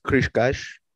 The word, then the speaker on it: Cascais: I think it might be Cascais, which is where it's actually pronounced Cascais.